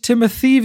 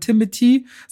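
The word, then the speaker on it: Timothée: Der gute Timothy Chalamet, der nicht Timothy, wie ich es immer gesagt habe, Ewigkeiten ausgesprochen wird, sondern Timothy, sagt er zumindest selbst, Chalamet.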